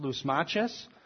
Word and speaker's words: Lysimachus: This is actually pronounced Lysimachus.